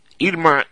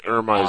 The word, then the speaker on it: Irma: Irma is the Spanish translation. Irma is the way Americans say it.